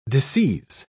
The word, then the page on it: disseize: British English